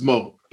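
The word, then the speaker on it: Mo3: So first of all, there's a guy called, I think it's pronounced Mo, but it's M-O-3.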